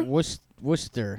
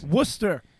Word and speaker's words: Worcester: Jason is telling us it is pronounced Worcester? – Worcester. – Like Worcestershire sauce? Worcester? – I guess. – Worcester, Massachusetts. Worcester.